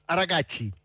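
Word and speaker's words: Araghchi: El ministro de Relaciones Exteriores que se llama Abbas Arakachi, se pronuncia así, pero supongamos.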